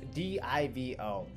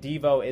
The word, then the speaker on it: DIVO: Now, I've heard this one being pronounced Devo and Divo. I actually think Devo is the correct pronunciation based on an interview that I heard from the company itself, but I'm actually just going to be calling it DivO because it sounds like the first syllable of dividend and O, so we obviously know that there must be some Spanish masculine conjugation going on.